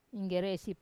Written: inggeresi

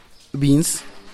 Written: bins

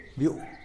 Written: biuu

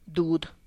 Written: dud

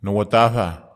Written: nowatahwa

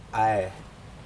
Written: ae

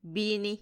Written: bini

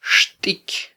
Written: xtiky

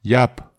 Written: iap

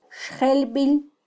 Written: xjelbil

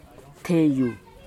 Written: hteiu

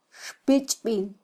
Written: xpichbil

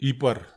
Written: ipar

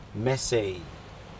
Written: mesei

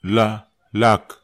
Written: la

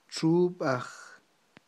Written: txub'aj

